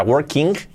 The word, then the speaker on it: working: You must have been working, o sea, working, eh, no, working. Y la G prácticamente no se pronuncia.